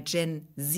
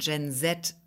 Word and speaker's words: Gen Z: Es ist überhaupt beides gar nicht falsch. Weil Gen Z ist einfach amerikanisch ausgesprochen und Gen Z ist englisch ausgesprochen.